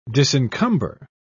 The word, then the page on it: disencumber: British English